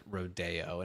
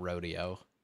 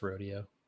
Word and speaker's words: Rodeo: Especially considering it's pronounced Rodeo and not Rodeo. – It's just Rodeo.